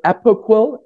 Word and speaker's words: Apoquel: So it's Apoquel, A P O Q U E L.